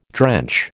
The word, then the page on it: drench: American English